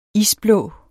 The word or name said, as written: isblå